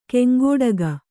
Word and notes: ಕಂಕಟಕ: kaŋkaṭaka

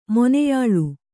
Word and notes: ಮೊನೆಯಾಳು: kannada

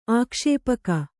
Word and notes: ಆಕ್ಷೇಪಕ: ākṣēpaka

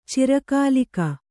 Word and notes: ಚಿರಕಾಲಿಕ: kannada

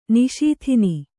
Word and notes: ನಿಷೂದನ: kannada

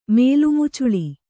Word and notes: ಮೇಲ್ಮುಚ್ಚಳ: mēlmuccaḷa

